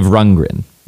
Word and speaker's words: Rundgren: She is born Liv Rundgren. – Rundgren. – Rundgren. Rundgren. Rundgren. – Rundgren. D in the middle? – Yes. – Okay. – I think usually not pronounced.